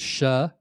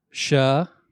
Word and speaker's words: She: Now, this is an unreached people group called the, it looks like she, but it's pronounced shuh. So the shuh.